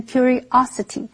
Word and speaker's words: curiosity: 我被橱窗里面漂亮的摆饰所吸引了。接下来我们看 curiosity 这个不可数名词。C-U-R 这个字根有关心的意思，所以 curiosity 是指好奇心或者是求知欲。注意它的重音是在 O 这个音节，念成 curiosity。